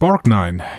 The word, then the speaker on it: Borgnine: Ernest Borgine, Borgine, ich weiß nicht, wie er ausgesprochen wird. Wenn du den googelst, dann den, den kennst du, glaube ich. Ernest Borgnine. Borgnine. Ja. Wird er, wird er so ja. gesprochen? Ja, ja.